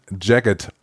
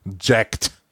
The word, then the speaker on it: Jagged: Ich habe wirklich nochmal nachgeschaut im äh, Aussprache-Wörterbuch, wie man Jagged ausspricht. Und es ist Jagged, nicht Jacked.